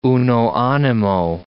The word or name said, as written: uno animo